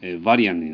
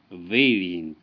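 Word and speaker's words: Varian: バリンバリンっていろいろ言ってましたら、あの NMR の、バリアンになっちゃって、とんでもないことになってしまったんですけども、これはヴェイリンって発音するんですね。ヴェイリンです。ですから A は A と発音する、この場合ヴェイリンですね。そういうことがよくあるということを頭に入れておくと良いと思います。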